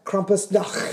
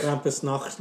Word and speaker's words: Krampusnacht: Krampusnacht. – Krampusnacht.